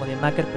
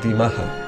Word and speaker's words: D-Maza: Sí, a mí me lo dijeron como D-Maker, pero como no tenemos... – Yo se lo he preguntado a María José antes de iniciar el podcast cómo se pronunciaba, o sea, es D-Maza.